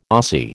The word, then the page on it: Ossie: American English